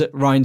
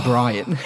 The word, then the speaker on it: Rian: Well, I'm going to say, in the documentary, the director and the Jedi, Luke, not Luke, Mark Hamill says Rian Johnson's name wrong. He calls him Brian.